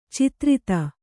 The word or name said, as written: ಚತುರ್ಥಿ